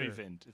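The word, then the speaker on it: Øyvind: Oyvind. It's like a U. It's pronounced like a U, I think. Kind of like a U.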